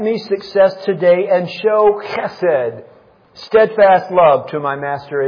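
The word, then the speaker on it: chesed (חסד): C-H-E-S-E-D, chesed. It has kind of a, you have to kind of get the in there. Otherwise, it's spelled H-E-S-E-D, chesed. But now you got to get the C-H in there, chesed.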